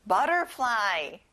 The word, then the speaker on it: butterfly: 친구들, T 발음은 안 나요. butterfly.